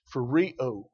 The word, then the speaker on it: phrureo: It's phrureo. Phrureo is that word. That's how it's pronounced.